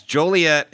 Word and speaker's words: Joliet: Nope, it's Joliet, and you said it wrong. – Oh, fuck those people. It's this weekend. It's done now. You haven't said it right one week. – Come. Yeah. I don't want you to come if you can't pronounce your own town.